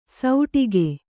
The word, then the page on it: ಸೌಟಿಗೆ: sauṭige